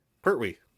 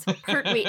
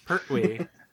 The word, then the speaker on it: Pertwee: The T goes in the first syllable, not Pertwee. The second syllable. Oh. It's not Pertwee. It's Pertwee. It's a B-dubs all over again. – Pertwee.